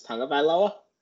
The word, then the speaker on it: Tagovailoa: I think the proper pronunciation is Tagovailoa. But it's spelled Tagovailoa. But it's tomato.